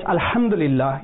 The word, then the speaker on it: Alhamdulillah: And if you pronounce the wrong word, so when it's Alhamdulillah, you pronounce it so that the ha, with the middle of the throat, is waziah.